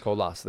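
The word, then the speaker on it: Colás: Do I pronounce it Colias or Colas, Richie? – I thought it was Oscar Colas. – Colas, there you go.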